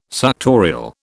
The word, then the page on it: suctorial: British English